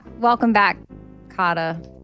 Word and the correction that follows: Kotter: You never say that right. Say it. Spell it for me. – Cotter. K-O-T-T-E-R.